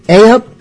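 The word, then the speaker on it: ay up: A up is a greeting from my home port in the East Midlands. Oh, Wait a minute. A rhymes with hey, by the way.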